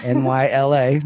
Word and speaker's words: Nyla: My good friend, Nyla. I said that wrong, but you told me how. So it's like New York to LA. – Yes. – NYLA, Nyla.